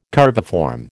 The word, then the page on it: curviform: American English